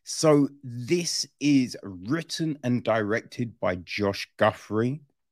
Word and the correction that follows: Guffey: Um, uh, oh, no, there's no R. I'm an idiot. It's Guffey right? I believe so. I feel that's how you pronounce it. Josh Guffey, right?